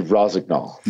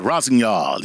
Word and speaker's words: Rossignol: Hey, give us the correct pronunciation, please. So we can get it wrong. – Yeah, it would be Rossignol. – Yeah, Rossignol, yeah.